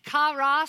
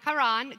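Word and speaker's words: chara: It's the word karan. There are several different pronunciations of it. Kara, sorry, not karan, kara.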